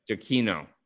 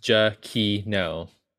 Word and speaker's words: Giacchino: It's spelled like Giacchino. It's like Frappuccino, but not. There's a G-I-A in front, and then there's Kino. – Giacchino. Giacchino. – Giacchino.